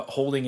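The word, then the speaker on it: Houlding: J-O-H-N-H-O-U-L-D-I-N-G. I feel very confident that Holding is the correct pronunciation there, but in case I'm spelling it like, in case I'm saying it wrong, it could be Holding.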